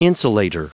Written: insulator

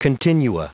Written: continua

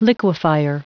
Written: liquefier

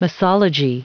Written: misology

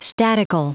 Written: statical

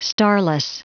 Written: starless

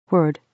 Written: word

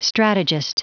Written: strategist

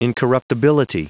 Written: incorruptibility

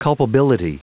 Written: culpability

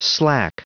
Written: slack

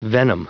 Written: venom

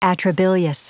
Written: atrabilious

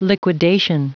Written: liquidation

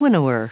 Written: winnower

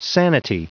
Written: sanity

